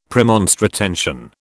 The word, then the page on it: Premonstratensian: British English